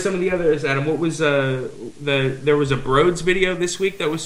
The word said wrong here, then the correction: Broods: Isn't it Broods? – I think so. – That's right, Broods. Laugh, L-A-F.